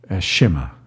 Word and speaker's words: Shema: And on the back of this thing was the Shema prayer. Is that how you say it? – Shema.